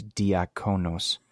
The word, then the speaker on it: diakonos: And it actually sounds like the word deacon, but it's diakonos.